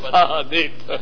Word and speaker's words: Ta'anit: Tainus. – Ta'anit. Okay. If you insist, it's Ta'anit. I'm pronouncing it the way, you know, pre-Israeli pronunciation.